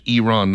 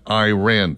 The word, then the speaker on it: Iran: Proper pronunciation is Iran, not Iran.